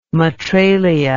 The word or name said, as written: Matralia